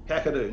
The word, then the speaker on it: Kakadu: Is that how you pronounce it? – Kakadu? – I'd say so, yeah. Kakadu National Park.